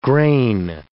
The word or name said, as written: grain